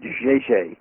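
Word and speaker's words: Žeželj: Did I pronounce that right? – He pronounces it Zezelj. As far as I have trouble with it too. – Where is he from? – It's Zezelj. He is from Croatia and is an amazing graphic artist.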